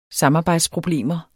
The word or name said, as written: samarbejdsproblemer